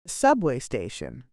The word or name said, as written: subway station